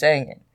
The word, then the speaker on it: Angen: He mispronounced her name so many times even the judge said something to him, like, is it Ingen or Angen? And he's like, oh, yeah, it's Angen.